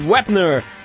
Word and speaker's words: Wepner: está falando de boxe aqui, o ex-pugilista Chuck Wepner está pedindo uma indenização de 15 milhões de dólares a Sylvester Stallone. Gostou da pronúncia, Arthur? Tá impecável.